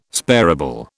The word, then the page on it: sparable: British English